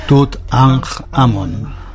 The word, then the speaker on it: Tutankamón: Cuando he dicho Tutankamón, no sé si se lo pronunciáis vosotros así. – Tutankamón. – Pues así como tú dices.